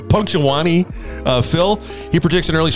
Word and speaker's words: Punxsutawney: How do you say that? Punxsutawney? Punxsutawney? – Punxsutawney. Yeah, now Punxsutawney.